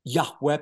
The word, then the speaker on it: Yahweh: And so Yahweh, and this is the best that we are aware, according to our Semitic scholars, of how to pronounce his name, Yahweh, because in the Hebrew alphabet, we don't have vowels. There's only consonants.